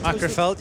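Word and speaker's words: Macclesfield: I said that right, Macrofeld. – Macrofeld, yeah, that's good.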